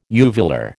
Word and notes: uvular: American English

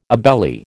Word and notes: obeli: American English

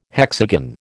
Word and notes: hexagon: American English

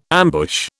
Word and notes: ambush: British English

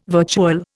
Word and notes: virtual: Indian

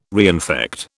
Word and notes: reinfect: British English

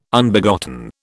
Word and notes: Unbegotten: British English

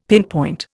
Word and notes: pinpoint: Indian

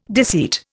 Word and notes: deceit: Indian

